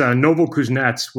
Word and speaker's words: Novokuznetsk: It's Novokuznetsk, which is right in the middle of Siberia, 5 hours east flight from Moscow.